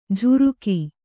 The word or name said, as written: ಝುರುಕಿ